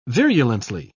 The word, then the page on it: virulently: British English